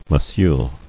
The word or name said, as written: Monsieur